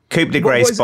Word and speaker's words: Coup de Grace: So, how are we pronouncing it? There's a lot of French in 2021. There's a lot of French pronunciations. – Mate, what is it on West Side? – Coup de Grace by Ransom and Rome Streets.